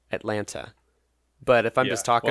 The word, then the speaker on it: Atlanta: It said, true or false, there are less than 2 T's in the correct pronunciation of Atlanta. – That sounds like Yankee talk to me. – I just said Atlanta, and I'm from Atlanta, which is how I usually say it.